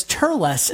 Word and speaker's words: Turles: And what I love here is they are using the Funimation-only name change for the villain of DBZ Movie 3, which is pronounced as Turles in the Funimation dub.